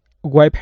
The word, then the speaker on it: wiped: So that ED on the end there is pronounced as a T this time. He just left off any sound whatsoever on the end of that verb. It should be wiped.